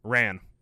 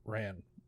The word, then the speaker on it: Ran: It's 1985's Ran, directed by Akira Kurosawa. – Yes, Ran. – Ran.